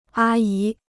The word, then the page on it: 阿姨: ā yí